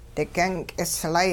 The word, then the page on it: digyung k'islay: hupa